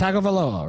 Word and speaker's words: Tagovailoa: It's Tagovailoa, right? – No, I thought it was like Tungavailoa. – Tungavailoa. – Where's the N anyway? – There is no N.